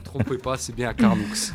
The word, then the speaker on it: Carnoux: Ça se prononce Carnoux.